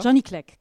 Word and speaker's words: Johnny Clegg: Du hast mir schon verraten, wie der Interpret richtig ausgesprochen wird. Johnny Clegg, ja? – Johnny Clegg, ja.